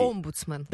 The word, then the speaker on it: омбудсмен: Знаешь, что правильно говорить, омбудсмен? – Да какая разница, он может появиться в России.